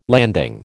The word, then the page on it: landing: American English